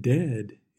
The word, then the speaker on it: dead: And dead, it's said like bed, not bead. For goodness sake, don't call it deed.